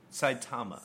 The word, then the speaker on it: Saitama: Coming from the Sataya Superdome in Japan, we have the monster. – The what Superdome? Saitama. – Saitama Superdome. I knew I said it wrong.